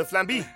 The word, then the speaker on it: flambé: El limón flambide. – No, the flambé. It's the flambé. – It's a flambé. – It's like plan B.